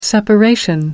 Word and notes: separation: American English

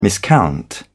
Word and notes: miscount: British English

